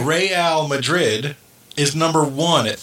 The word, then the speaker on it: Real: Is it pronounced Real Madrid or Real Madrid? – Real, as in royal.